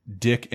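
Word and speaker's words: Dic: Super Show because it was made by the awful Dick Animation Group. Yes, I don't pronounce it Deke. It is Dick.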